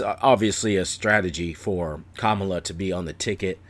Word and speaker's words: Kamala: It's not Kamala. It's Kamala. Like, comma. And then L.A., Kamala Harris.